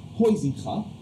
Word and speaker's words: Huizinga: One of the most important game theorists, a Dutch gentleman who I previously called Johan Huizinga, until a Dutch friend informed me it was pronounced Huizinga,